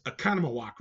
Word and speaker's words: Oconomowoc: And it's Oconomowoc, right? Did I say it right? – You did, that's correct.